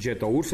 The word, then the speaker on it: Jetour: y me refiero específicamente a la marca Yetour. Yetur ya está en México y déjenme meterme a su página rápido que debe ser, aquí estoy, Jetour MX, Jetour, se los digo así porque así se escribe,